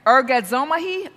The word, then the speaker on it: Ergazomai: Ergazomai, ergazomai, E-R-G-A-Z-O-M-A-I is how it's spelt, and that's how it's pronounced.